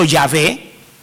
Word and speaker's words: Yahvé: Entonces vino alguien y trató de armar una palabra metiéndole unas vocales de manera que pudiésemos pronunciarlo. Por eso Yahvé y por eso Jehová. Es lo mismo. Puede ser Yahvé o puede ser Jehová.